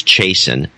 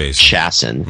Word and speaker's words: Chasen: He sent me an email saying it's pronounced like Jason, so it's Chasen, not Chasen.